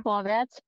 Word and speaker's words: Vorwärts: So, okay, tell me, correct my pronunciation one time. Vorwärts, immer. – Vorwärts. – Vorwärts, vorwärts. – Vor. – Vor. Vorwärts. Vorwärts. Immer.